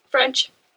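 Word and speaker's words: ffrench: Well, her name's Caitlin, I know that much, but her surname, I want to pronounce it French. – Yes, there is more than one F at the beginning. – But how would you pronounce that? – French.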